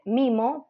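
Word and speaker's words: Meemo: La página es, se escribe distinto que cómo se pronuncia. Se dice mimo, pero se escribe Memo con doble E, M-E-E-M-O, punto vet es la página.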